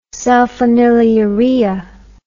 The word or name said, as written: sulfonylurea